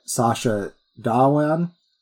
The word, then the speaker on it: Sacha Dhawan: So did Count Orlo, played by Sacha Dhawan.